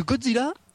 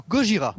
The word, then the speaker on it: Gojira: Alors, à savoir que Godzilla, c'est la prononciation occidentale de Gojira.